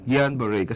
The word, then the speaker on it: Guillain-Barré: G-U-I-L-L-A-I-N-B-I-R-R-E. – Guillain-Barré syndrome. Mm. Tinanong ko sa nanay ko, di pari pronounce eh. Sabi niya, Guillain-Barré, kasi pangalan ng tao yan.